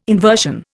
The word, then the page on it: inversion: Indian